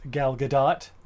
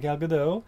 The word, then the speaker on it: Gal Gadot: this movie, of course, stars Gal Gadot. That's how you say her name, apparently. – Not Gal Gadot.